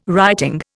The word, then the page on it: writing: Indian